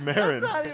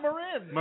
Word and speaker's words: Marin: That's Cheech Marin. – I've heard a lot of people lately pronounce it Marin. – Marin is how to say it in Spanish. He kind of Americanized the area in California.